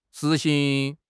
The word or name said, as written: 实行